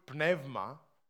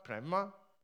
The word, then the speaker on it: pneuma: The Ruach of God, meaning the pneuma of God in Greek, Ruach in Hebrew, pneuma, pneuma, pneuma, right pronunciation in Greek, which is the breath of God, the Spirit of God.